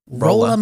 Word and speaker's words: Rolla: It's R-O-L-L-A, Rolla. – Rolla, Missouri. – We'll say Rolla.